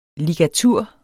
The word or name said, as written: ligatur